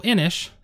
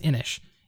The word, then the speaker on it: Inis: So Inish, it's spelled I-N-I-S, and it is a Celtic word, or I don't know if it's a deity in the ancient Celtic society. – I'm going to Google it. – Go for it. But I believe it's pronounced Inish. It is an awesome dudes on a map game.